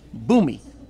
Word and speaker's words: Bhoomi: He launched the company Bhumi. Bhumi is spelled B-H-O-O-M-I.